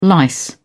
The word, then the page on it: lice: British English